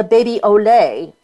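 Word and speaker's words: Bebe au Lait: Here's another one that you might like, is the Baby Olé, and I'll spell that for you. It's B-E-B-E-A-U-L-A-I-T. It's actually three separate words.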